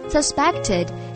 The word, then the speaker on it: suspect: Suspected 是指被怀疑可能存在的。 Suspect, 则是指受到怀疑的、不可信的。注意发音时重音在 p 的前面。